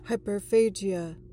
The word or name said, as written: hyperphagia